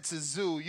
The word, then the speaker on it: Tszyu: The T is solid. I'm at Tszyu.